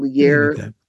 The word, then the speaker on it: Lierre: And Lier is spelled L-I-E-R-R-E Keith, K-E-I-T-H.